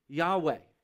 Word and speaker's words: Yahweh: It's the name Yahweh. That's how to pronounce the word L O R D when it's in small caps.